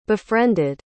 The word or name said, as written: befriended